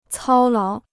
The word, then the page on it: 操劳: cāo láo